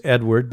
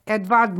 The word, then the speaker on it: Edvard: His name was Edward, but they don't pronounce it that way. – Edvard Munch? – Yes, Munch.